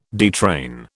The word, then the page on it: detrain: British English